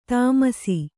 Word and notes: ದಾಮಾಷಾ: dāmāṣā

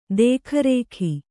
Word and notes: ದೇಖರಖಿ: kannada

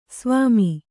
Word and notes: ಸ್ವಾಮಿ: svāmi

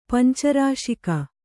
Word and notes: ಪಂಚರಾಶಿಕ: kannada

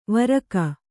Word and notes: ವರಕ: varaka

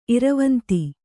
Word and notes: ಇರವಂತಿ: iravanti

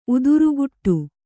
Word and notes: ಉತ್ತರಕೊಡು: uttarakoḍu

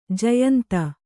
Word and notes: ಜಯಂತಿ: jayanti

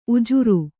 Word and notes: ಉಜರು: kannada

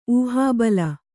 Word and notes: ಊಹಾಬಲ: ūhābala

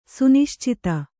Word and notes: ಸುನಿಶ್ಚಿತ: suniścita

